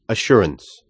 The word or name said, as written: assurance